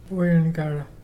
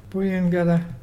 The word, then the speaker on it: Buyungarra: Puyengara. – What is a? – Puyengara is turtle, and it's spelt B-U-Y-U-N-G-A-R-R-A.